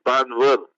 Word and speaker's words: Banvat: They spell it Banvat, but you pronounce it Banvar.